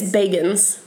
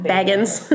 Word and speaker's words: Bagans: Is it Bagans or Baggins? – It's Bagans. – Oh. – Because I've been calling him Baggins.